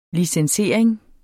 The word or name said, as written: licensering